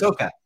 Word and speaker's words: Ahsoka: I can never pronounce that. Wait, is that Ahsoka? Is that how you pronounce the Star Wars? – Ahsoka. – Ahsoka, okay.